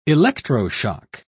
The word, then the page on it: electroshock: British English